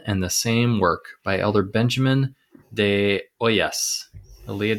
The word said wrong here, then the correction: Hoyos: Hoyos. – Hoyos.